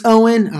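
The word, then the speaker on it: Eoin: I think his name is Owen. E-O-I-N. I think it's Owen.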